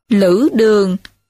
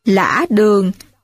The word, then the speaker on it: Lữ Đường: Lữ Đường cũng đọc là Lã Đường, chiếm giữ đất Tế Giang, nay thuộc huyện Mỹ Văn, tỉnh Hưng Yên, và xưng là Lữ Tá Công.